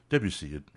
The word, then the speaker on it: Debussy: Oh, Debussy.